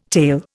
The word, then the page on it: tale: Indian